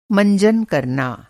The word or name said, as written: मंजन